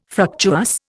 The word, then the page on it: fructuous: Indian